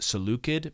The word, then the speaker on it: Seleucid: Actually, it should be pronounced Seleucid, but we're not going to be that lame. We're just going to call them the Seleucids. There's always a hard... When you see C in Greek, it's almost always a hard C, by the way.